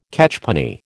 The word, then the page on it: catchpenny: American English